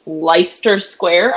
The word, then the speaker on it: Leicester Square: So there's Leicester Square, I think is the pronunciation of that.